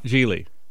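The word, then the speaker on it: Gigli: Geely.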